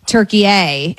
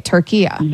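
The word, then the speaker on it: Türkiye: Okay, so it's not what we would call, you and I are making it a hard A, turkey A, and it's turkey-a, he's saying. So it's a soft A.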